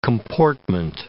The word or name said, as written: comportment